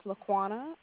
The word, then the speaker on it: Laquana: My name is Laquana, L-A-Q-U-A-N-A. – Can you spell that again? – L-A-Q-U-A-N-A.